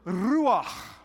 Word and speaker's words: ruach: The first part is an R, but you have to roll your R. So go, can you do that? Yeah, you're pitiful. All right. So when you put all that together, it is, the word is ruach.